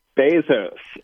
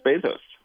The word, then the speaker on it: Bezos: Bezos or Bezos? How do you pronounce his name? – It is Bezos, yes.